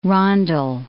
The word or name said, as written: rondel